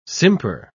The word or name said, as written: simper